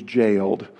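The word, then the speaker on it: gaol: he was jailed. The way they spelled that in those days was with a G. It's pronounced the same way as if we had written it with a J.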